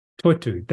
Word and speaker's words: Toitū: I think so, it's a Toitū? – Toitū, that's the one, yes. Well pronounced.